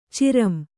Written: ಚಿರಂ

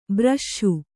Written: ಭೃಶ

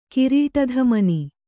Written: ಕಿರೀಟಧಮನಿ

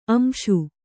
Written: ಅಂಶ

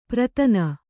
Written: ಪ್ರತನ

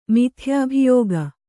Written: ಮಿಥ್ಯಾಭಿಯೋಗ

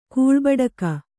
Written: ಕೂಳ್ಬಡಿಕ

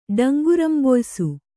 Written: ಡಂಗುರಂಬೊಯ್ಸು

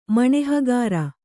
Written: ಮಣೆಹಗಾರ

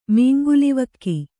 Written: ಮೀಂಗುಲಿವಕ್ಕಿ

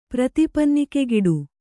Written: ಪ್ರತಿಪನ್ನಿಕೆಗಿಡು

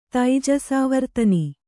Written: ತೈಜಸಾವರ್ತನಿ

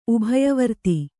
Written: ಉಭಯವರ್ತಿ

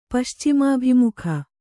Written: ಪಶ್ಚಿಮಾಭಿಮುಖ

